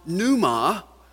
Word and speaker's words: pneuma: It's the name, it's the word pneuma, spelled P-N-E-U-M-A with a silent P, pneuma.